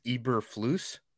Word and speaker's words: Eberflus: It looks like it's Eberflus. – Eberflus, so, not Fluss.